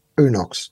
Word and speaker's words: UNOX: In terms of pronunciation, I think I would hate to think that I do not pronounce it correctly, but I say UNOX. I've heard it pronounced a couple of different ways. So can you tell me the correct pronunciation for your business? – UNOX.